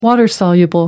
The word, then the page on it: water soluble: American English